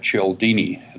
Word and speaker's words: Cialdini: There's a great book written about 15 years ago by Robert Cialdini. It's spelled C-I-A-L-D-I-N-I, Cialdini.